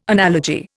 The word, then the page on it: analogy: Indian